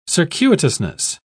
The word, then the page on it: circuitousness: British English